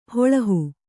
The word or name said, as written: ಹೊಲ್ಲಹ